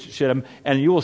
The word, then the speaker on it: shittim: What they're burning in Hebrew is spelled S-H-I-T-T-I-M. Well, that word, and it is pronounced exactly that as it's shittim, is a Hebrew word, and it means acacia.